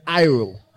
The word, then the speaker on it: airo: He takes away comes from the Greek word airo. Airo is spelled A-I-R-O, airo.